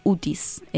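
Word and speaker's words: utis (οὔτις): Quiero decir una cosa sobre la palabra nadie en griego que es utis, es ou, que es la negación, se, se pronuncia u pero se escribe ou,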